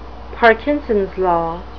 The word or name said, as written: parkinsons law